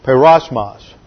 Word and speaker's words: peirasmos: Now, the very first word in the Greek text is the word perosmos. Which is spelled in English P-E-I-R-A-S-M-O-S.